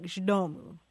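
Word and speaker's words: z domu: It's Z space D-O-M-U. And it's pronounced something like zdomu.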